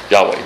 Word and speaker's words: Yahweh: Some people pronounce it Jehovah, but it's really Yahweh.